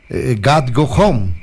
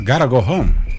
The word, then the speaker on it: Gotta Go Home: God go home, ¿es así? – ¿Cómo se escribe? – Quiero ir a casa. – Gotta go home.